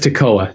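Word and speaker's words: Toccoa: Tococo, Georgia. – Close. – Go ahead. – Yeah. – Go. Yeah. How do you pronounce it? – Just Tocoa. There are two C's, but they're right together.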